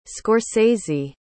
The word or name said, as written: scorsese